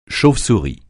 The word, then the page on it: chauve-souris: français